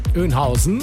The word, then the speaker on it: Oeynhausen: Wie würdest du es denn aussprechen? – Was denn bitte? Kreuz? – Kreuz Bad Oeynhausen. Auch so? – Oeynhausen. – Ja, genau.